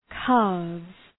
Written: calves

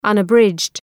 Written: unabridged

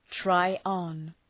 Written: try on

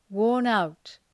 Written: worn-out

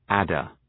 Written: adder

